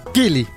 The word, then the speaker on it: Kili: A ver, es K-I-L-I. Sí, señor. – ¿Qué quiere decir? No, no, va a preguntar mucho, eh, compadre, porque. este. Y acento en la primera I.